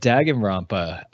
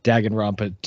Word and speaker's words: Danganronpa: And then this week we get Danganronpa.